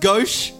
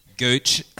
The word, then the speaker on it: gauche: Is it not pronounced gauche? – I think it is, but I initially thought it wasn't. – Absolutely, yeah, it's fine.